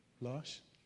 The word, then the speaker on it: L'Arche: the L'Arche community. Is that the right pronunciation? L'Arche? Yeah.